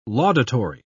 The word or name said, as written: laudatory